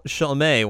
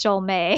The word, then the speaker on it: Chalmé: So the Herlock Chalmé one. – Which, they gave him an accent on the ending E for his name, and that was really bothersome because now it's pronounced Chalmé.